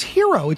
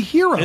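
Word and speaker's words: Herro: Basically, what's the best way for Jimmy Butler to honor Tyler Harrell? – Oh, good point. – Or Hero. – Tyler Hero? Oh, I know. I keep hearing it mispronounced. It's Hero. It's just simply Hero.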